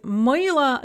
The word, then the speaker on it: Мила: With мила, Лена, and Нина, we have an O or an A that is after the stress, and so they're all pronounced kind of an E sound. Мила, Лена, Нина.